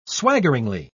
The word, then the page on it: swaggeringly: British English